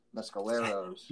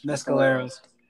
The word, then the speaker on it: Mescaleros: Or would you rather see Joe Strummer and the Mescalones? – Mescaleros. – Mescaleros.